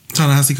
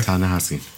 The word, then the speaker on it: Ta-Nehisi: Ta-Nehisi? – Ta-Nehisi Coates? – Yes. – Okay. – Ta-Nehisi.